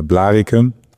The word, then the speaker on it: Blaricum: Hilf mir bitte bei der Aussprache. Blaricum? Wie nennt man den Ort richtig? – Ja, Blaricum. Aber ja.